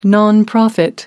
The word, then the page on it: nonprofit: British English